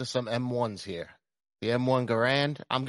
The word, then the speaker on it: Garand: I mean, his name was Garand. That's how we pronounce his name.